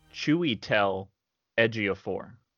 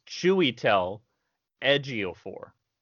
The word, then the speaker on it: Chiwetel Ejiofor: Chiwetel Ejiofor is how his name is pronounced. Why don't people call him Chewy? That's what I want to know. – Well, how do you even get Chewy? Isn't it an I? – I looked it up. He pronounced it. It was Chiwetel Ejiofor.